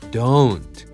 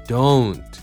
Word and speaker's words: don't: つまりね、このね、 do じゃなくて、 oh、 do、ね、 o じゃなくて、 oh っていう二重母音を使ってほしいんだよね。 don't、 don't。